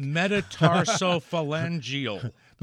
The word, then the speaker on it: metatarsophalangeal: Metatarsophalangeal. – You said it absolutely perfectly.